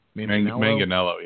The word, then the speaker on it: Manganiello: Is it Manganiello? – Manganiello, yeah.